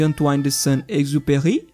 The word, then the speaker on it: Antoine de Saint-Exupéry: Passando pela delicadeza de O Pequeno Príncipe, de Antoine de Saint-Exupéry, Uhum. Há no total. Parabéns pela pronúncia.